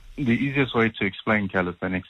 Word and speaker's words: calisthenics: A chair of the South African Street Workout and Calisthenics Association. Help me with the pronunciation of that word, please. – Hi. It is a bit of a tongue twister. It's calisthenics.